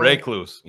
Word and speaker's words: recluse: He has a cane because he lost a toe because he got bit by a brown lacrosse spider. I'm saying it wrong. – Recluse. Yeah. – Recluse. Oh, yeah. Recluse.